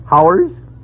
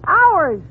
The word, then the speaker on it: hours: Now, how do you pronounce H-O-R-S-E? – H-O-R? Oh, that's easy. That's horse. – Fine. Now, how do you pronounce H-O-U-R-S? – H-O-U-R-S? Howers? – You pronounce it ours.